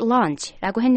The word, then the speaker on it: launch: 자, 점심이라는 뜻의 launch, L-U-N-C-H. Launch와 발음이 좀 비슷한 것 같은데 발음 좀 해주시겠어요? – Launch, L-A-U-N-C-H. 네, launch는 A-U, 그 아 발음이 좀 더 길고요. – 네. – Lunch, 점심. Lunch는 좀 짧습니다.